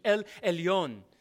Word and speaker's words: El Elyon: So write that down, E-L, and then E-L-Y-O-N. Pronounced from the original language, that's the way it transliterates into English. El Elyon.